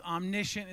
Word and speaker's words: omniscient: And just so you know, it's not omniscience. It's omniscient is how you pronounce it.